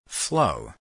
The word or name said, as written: flow